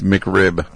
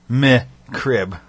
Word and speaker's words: McRib: I guess it's because she kind of pronounces it McCrib. – McCrib? Bitch, you can't even say it right.